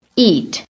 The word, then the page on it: EAT: American English